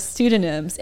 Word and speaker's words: pseudonyms: And the word was pseudonyms, and the silent P was really getting him.